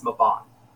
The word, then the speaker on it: Mabon: how do you pronounce this like ridiculously Welsh name? And he told me, and it's Mabon.